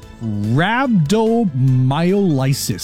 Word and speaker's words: rhabdomyolysis: So it's called rhabdomyolysis, spelled R-H-A-B-D.